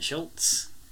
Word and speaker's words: Schultz: And in Hollow Pursuits, we get a brilliant guest star, Dwight Schultz.